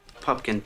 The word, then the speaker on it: Pupkin: Pupkin, P-U-P-K-I-N.